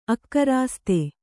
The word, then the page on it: ಅಕರಾಸ್ತೆ: akarāste